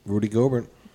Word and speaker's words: Rudy Gobert: Rudy Gobert. – I think that's how it's pronounced, right? – Yeah, we're going to get...